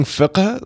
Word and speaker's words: fiqh: But you pronouncing fiqh like it's it's hundred percent. Your Arabic is on point.